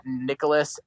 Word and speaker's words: Nicolas: You can tweet at me at Nicolas, N-I-C-O-L-A-S. It's spelled the Spanish way. My mom's from Argentina. Deal with it. Nicolas Sick, S-C-I-C, the beginning of my last name.